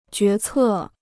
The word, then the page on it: 决策: jué cè